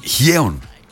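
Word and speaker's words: Hyeon: Γκιου. – Γκιου. – Χιέον προφέρεται. – Πώς? – Χιέον. Ναι. Αλήθεια σου λέω, παιδί μου. Χιέον. Προφέρεται Χιέον.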